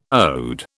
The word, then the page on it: ode: British English